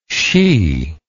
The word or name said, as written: sidh